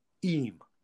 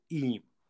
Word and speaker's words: Eyam: And I introduced a village called Eam. Now, I'm from Derbyshire. That's how I pronounce it. It's called Eam. It's spelled E-Y-A-M.